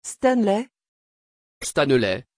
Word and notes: Stanley: French

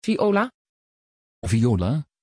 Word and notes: viola: Dutch

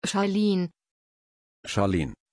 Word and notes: charline: German

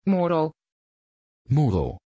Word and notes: Moro: Dutch

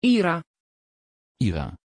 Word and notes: ira: Dutch

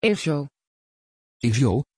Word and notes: ézio: Dutch

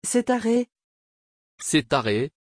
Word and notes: Setareh: French

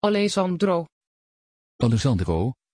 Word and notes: Alesandro: Dutch

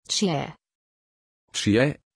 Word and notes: chie: German

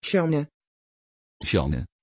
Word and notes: Shane: Dutch

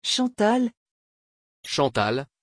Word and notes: chantal: French

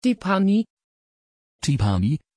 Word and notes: tiphanie: Dutch

